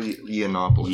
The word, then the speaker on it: Yiannopoulos: How do you say his name? – Milo Yiannopoulos.